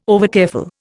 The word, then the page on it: overcareful: Indian